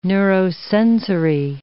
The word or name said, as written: neurosensory